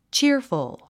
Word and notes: cheerful: tʃíə r fəl; チアフゥ

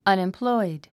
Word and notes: unemployed: ʌ̀nimplɔ́id; アネンプロイド

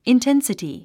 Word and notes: intensity: inténsəti; インテンスィティ

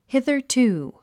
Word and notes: hitherto: híðə r tùː; ヒザァツゥ

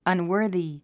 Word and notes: unworthy: ʌnwə́ː r ði; アンウォージィ

